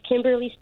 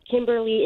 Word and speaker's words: Kymberly: Okay, so it is Kimberly Stewart. Kimberly is spelled K-Y-M-B-E-R-L-Y, and Stewart is S-T-E-W-A-R-T.